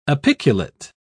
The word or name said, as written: apiculate